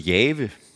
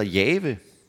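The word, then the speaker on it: Jahve: Nogle vælger at udtale det som Jave. Sådan kunne man vælge at udtale det. Altså, at Gud er Herren. Gud hedder Jave.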